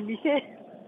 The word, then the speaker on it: 미세: 미쇠? 미세 예. 예. 아닙니까? 미세? 예, 예 미세. 자, 발음을 제대로 해주시기 바랍니다. 많은 국민들이 듣고 계십니다. 미쇠가 예. 아니고 미세.